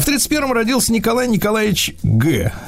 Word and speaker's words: Ге: Нет, дело в том, что э, французский его фамилия писалась Гей. Ах, вот. А читается Г.